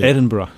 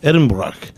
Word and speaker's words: Edinburgh: Edinburgh. – Edinburgh.